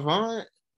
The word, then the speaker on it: Avant: Is it Avon? Avant? What's the man name? Cause I say his name wrong all the time.